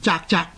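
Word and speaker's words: 啧啧: 那这个正確的读音呢，是应该读做人言责责，即是责任的责字同音。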